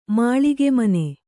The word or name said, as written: ಮಾಳಿಗೆಮನೆ